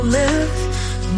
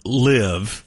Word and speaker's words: live: It said as they go live rather than live. Oh, she mispronounced live. It's supposed to rhyme with dive, which was more on dog took a dive.